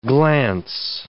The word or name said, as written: glance